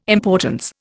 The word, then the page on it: importance: Indian